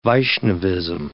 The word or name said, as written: Vaishnavism